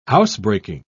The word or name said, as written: housebreaking